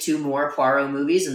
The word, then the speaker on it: Poirot: Poirot, is that correct? – Poirot.